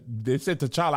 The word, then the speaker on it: T'Challa: They said T'Challa out loud. I was like, oh. I thought it was Shala. – Shala. – I was like, them is 3 consonants together.